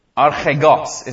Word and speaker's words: archegos: It's the word archegos, is how you pronounce that.